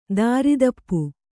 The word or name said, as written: ದಾರಿದಪ್ಪು